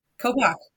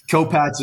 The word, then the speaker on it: Kopach: Kopak. – Yep. H is silent. You're good. – Kopachi. – Yeah. Kopach is the most common, but yeah, the H is silent.